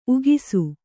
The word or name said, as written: ಉಗಿಸು